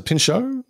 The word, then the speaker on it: Pinchot: And they've just wrapped Beverly Hills Cop 4, I believe, because a mate of mine in the States is working with Bronson Pinochet, is that how you say his name? – Is it Pinchot? – Pinchot. Pinchot? Serge. Pinchot.